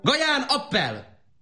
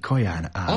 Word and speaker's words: Kaján Ábel: Gaján, appel! Gaján, appel! – Azt akarja mondani Kaján Ábel. – Appel, Abel. – Csak hogy rendkívül liftói kiejtéssel beszéli Abel a magyart.